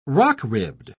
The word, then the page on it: rock-ribbed: British English